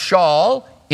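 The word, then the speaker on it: Shaul (שאול): It would be pronounced Shaul in Hebrew, and it speaks of Saul, the first king of Israel.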